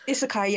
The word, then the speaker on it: Isekai: Isekai, yes.